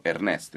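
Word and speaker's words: earnest: Però si pronuncia allo stesso modo del nome Ernest, che è appunto Ernest in italiano, anche se scritto in maniera diversa, manca la A.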